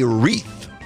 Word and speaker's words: wreath: W-R-E-A-T-H. What do you have on the door during Christmas? A wreath. I think this is a tough one to end on, by the way. It's not easy to spell for me. Wreath. There's a silent W to start with.